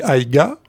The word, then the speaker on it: AIGA: Alors, ça s'écrit AIGA. Donc notamment si vous voulez aller sur le site internet, il faut effectivement écrire comme ça. Et après les gens prononcent, moi je dis AIG, mais certains prononcent AIGO, puisqu'effectivement la prononciation de l'occitan peut varier selon les endroits et selon les régions.